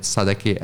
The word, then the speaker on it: Sadecky: So first name is Pavol, P-A-V-O-L. Last name is Sadecky, S-A-D-E-C-K-Y.